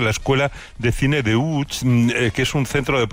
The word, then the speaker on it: Łódź: Aunque se pronuncia Łódź, se escribe Łódź, por si alguien lo busca Łódź, en Google.